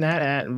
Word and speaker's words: AT-AT: Apparently that's pronounced an AT-AT, but...